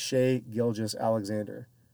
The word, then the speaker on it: Shai Gilgeous-Alexander: And I'll get his name right. His name's Shai Gilgeous-Alexander. Trying to say it too quick.